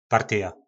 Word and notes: partyja: po nasimu

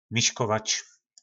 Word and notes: miskovac: po našimu

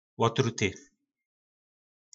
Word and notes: lotruty: po nasimu

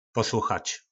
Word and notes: poslochac: po nasimu

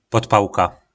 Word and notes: podpalka: po nasimu

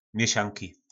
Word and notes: mjysanki: po nasimu

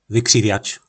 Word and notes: Vykrivjac: po našimu

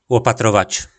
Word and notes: lopatrovac: po nasimu

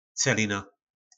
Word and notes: celina: po našimu

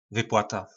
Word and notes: vyplata: po našimu

